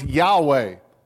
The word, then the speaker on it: Yahweh: You know, in the Old Testament, his name is pronounced Yahweh. Yahweh, well, actually, it wasn't pronounced at all because they didn't have vowels. We pronounce it Yahweh.